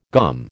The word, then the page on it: GOM: American English